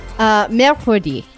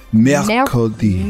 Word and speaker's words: Mercredi: What is Wednesday in French? – Mercredi. – Mercredi. – Mercredi. It's spelled Mercredi. – Mercredi. Not spelled so Mercredi.